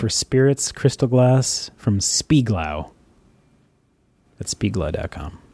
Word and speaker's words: Spiegelau: S-P-I-E-G-E-L-A-U. Spieglau? I'm assuming I'm pronouncing that somewhat correctly. – That sounds right.